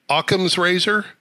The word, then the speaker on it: Occam's razor: In other words, he and many others have done the math and concluded that, correct my pronunciation, Occam's razor? – Yes.